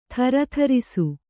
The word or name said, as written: ಥರಥರಿಸು